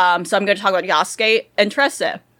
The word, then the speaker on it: Yasuke: But I think it's Yasuke. – Yeah, it's Yasuke, okay. Okay, yeah, like Sasuke, to be fair.